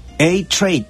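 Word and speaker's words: Eight Trey: イトレイっていうのが正確な発音なんですけども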